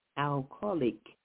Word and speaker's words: Al-Khaliq: First, let's get the spelling of it. That's capital A-L, capital K-H, A-L-I-Q, Al-Khaliq. And I actually had to get a correct pronunciation of that, and I certainly do appreciate it.